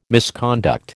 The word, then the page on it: misconduct: American English